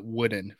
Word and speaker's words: Wooden: I saw in your comments people were mad that I pronounced it woolen, not wooden, for the defensive lineman rookie.